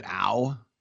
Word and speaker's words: Au: Is it Au? – Yes, just like ouch.